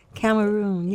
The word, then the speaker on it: Cameroon: And also, she's our new Miss Cameroon. Is that right? Did I say that right? – Excellent. – Cameroon, yes.